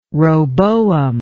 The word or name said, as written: roboam